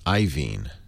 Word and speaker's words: Iovine: Table says Iovine is pronounced Iovine. I-O-V-I-N-E.